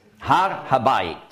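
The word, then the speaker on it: Har Habait (הר הבית): Der Tempelberg heißt Har Habait.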